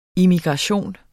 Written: immigration

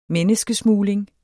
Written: menneskesmugling

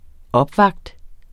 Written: opvakt

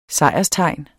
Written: SEJRSTEGNET

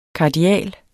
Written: kardial

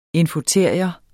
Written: infoteria

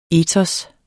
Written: etos

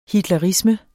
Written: hitlerisme

